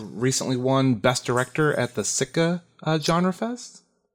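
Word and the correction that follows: Sitges: If I'm pronouncing that right, I think. – Sitches. – Thank you. Yep.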